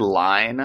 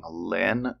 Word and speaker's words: Lyne: It's Ingrid Line. I think I said it correct at the beginning, but it's spelled L-Y-N-E, and I've been saying Lynn the past half.